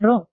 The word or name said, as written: rok